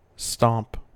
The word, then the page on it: stomp: American English